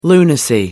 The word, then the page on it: lunacy: American English